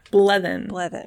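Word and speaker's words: Blethyn: Brenda Bledin, I think. – Bledin. Yes. Bledin. Brenda Bledin plays.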